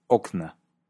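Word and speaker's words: okna (окна): El plural de ventana, akno, es okna. Pero además, como acabas de escuchar, algunas palabras experimentan cambio de acentuación, lo cual lleva a cambios en la pronunciación. Volvamos a escuchar. Dom, dama. Kot, kat. Akno, okna.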